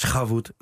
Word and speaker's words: Shavuot: Den er god, den man krasser i halsen. – Og det er lige præcis, og det er Shavuot. Nu sagde jeg det. Shavuot.